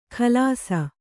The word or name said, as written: ಖಲಾಸ